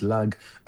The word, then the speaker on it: Lag: The letters, the Hebrew letters, Lamed Gimel, which pronounced Lag, are really 33, because in Hebrew we use letters to mean numbers as well.